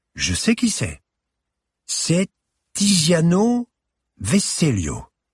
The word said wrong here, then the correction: Tiziano Vecellio: » Et elle massacra au passage la prononciation du nom. – « Oui, ma chère Mona, je te féliciterai de savoir lire un panneau quand tu amélioreras ton accent italien.